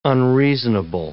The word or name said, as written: unreasonable